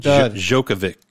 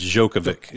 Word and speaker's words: Djokovic: Djokovic. Novak Djokovic. – It's Djokovic.